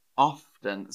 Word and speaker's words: often: So yeah, Miguel, I notice British people pronounce the letter T in the word often. Often. So it sort of depends, actually. Older British people would say often.